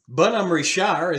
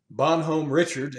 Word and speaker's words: Bonhomme Richard: No, it's the Bonhomme Richard is how the French would pronounce it. Bonhomme Richard, it might be an American's pronunciation.